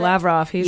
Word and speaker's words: Lavrov: Lavrov.